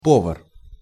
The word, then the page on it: povar: Russian